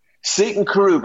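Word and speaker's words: Seaton Carew: Is it pronounced Seat and Carew? – Seton Carew, but now they call it Seton Canoe, and they have ice creams with canoes in it because it's so famous.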